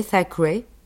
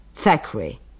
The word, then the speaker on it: Thackeray: Vous connaissez Thackeray? C'est bien comme cela qu'il prononce, hein? Thackeray. C'est bien cela, c'est bien comme cela qu'on dit.